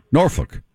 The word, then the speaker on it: Norfolk: Norfolk. – Right. Don't say folk. Don't do any. It's Norfolk. Norfolk. I can't say because it sounds like a curse to me. – But that's how it's said.